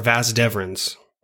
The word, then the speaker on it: vas deferens: And what that means is in 90, like 7 or 98% of cystic fibrosis patients, we don't develop our vas deferens. I pronounced that right? – Yep.